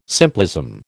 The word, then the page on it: simplism: American English